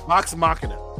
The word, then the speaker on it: Machina: Machina. Get it right. We're going to get beat up if you don't say it right. – I'm sorry, correct me. What is it again? – Vox Machina. – Yes. Mechana. Yes, you are correct.